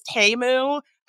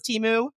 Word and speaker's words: Temu: Actually, apparently it's Temu. I've been saying it wrong this whole time, but I thought it was Temu.